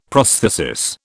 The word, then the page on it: prosthesis: British English